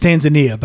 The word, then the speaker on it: Tanzania: It's Tanzania, but what did I pronounce it last week? – You said Tunisia. – Yeah. – Or Tanzania. – Tanzania or something like that. Yeah, it's Tanzania. Sorry.